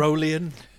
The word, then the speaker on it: Tyrolean: And hey, you're wearing the Tyrolean gear. Is that how you say it? Is that how you pronounce it? – Tyrolean.